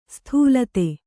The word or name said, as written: ಸ್ಥೂಲತೆ